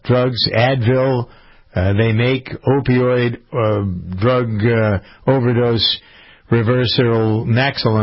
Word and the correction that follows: Naloxone: Am I saying that right? Naloxone, I guess it is. N-A-L-O-X-O-N-E.